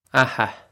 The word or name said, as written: Atha